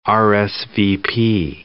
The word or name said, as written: RSVP